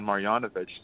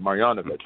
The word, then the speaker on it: Marjanovic: If you're going to pair him against like a Boban Marjanovic, see, that's how you say his last name right there, Marjanovic. I know hey! – Some other people might have different pronunciations, but that's how I'm going to go with it.